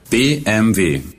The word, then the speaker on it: BMW: The reason technically it should be BMW is because in German, the letter W is pronounced like a V. Like you say, Auf Wiedersehen, to say goodbye. If you spell out Wiedersehen, it begins with a W.